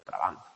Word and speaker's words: Trabant: Dice, más coherente, de entre los supuestos teóricos, es el de Jürgen Trabant, o Trabant, como se pronuncia.